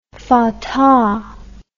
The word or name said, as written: Fatah